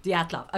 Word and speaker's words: Dyatlov: Oh my God, your pronunciation is so bad. It took me a minute. – What's yours pronunciation? – Dyatlov. – No, wrong. – They're very similar. – And it's Dyatlov. – Dyatlov.